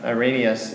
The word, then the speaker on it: Irenaeus: Irenaeus, he's using it almost the opposite of what, not Sproul, but whoever he was quoting, Gentry.